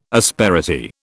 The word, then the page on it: asperity: British English